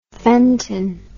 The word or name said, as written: fenton